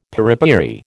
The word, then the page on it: piripiri: American English